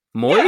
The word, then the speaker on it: Moyes: You don't say that two syllables? – Is no. it Moyes? – Moyes? – Yeah. yeah. like rhymes with boys.